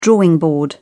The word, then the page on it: drawing board: British English